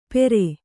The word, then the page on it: ಪೆರೆ: Kannada